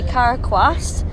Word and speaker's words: Cara Quast: I'm joined here by Cara Quast, new Steel Queens member.